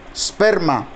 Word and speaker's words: sperma: La palabra simiente es sperma. S-P-E, acento en la E, S-P-E-R-M-A, sperma.